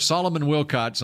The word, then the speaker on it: Solomon Wilcots: I heard Solomon Wilcots on NFL Sirius.